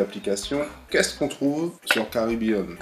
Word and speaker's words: Kribean: Alors, pour nos auditeurs, on précise que l'application qu'ils vont se précipiter d'aller chercher dans leur application store, ça s'écrit K-R-I-B-E-A-N, Caribbean.